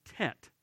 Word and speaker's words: Tet: You'll notice here tonight we pronounce this one Tet, like if you know your Vietnam history, the Tet Offensive.